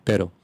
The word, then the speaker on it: pero: Pero. – Mm-hmm. And the animal that barks. – Perro. – So you see the difference? – There's a difference. – There's a difference. – Pero and perro.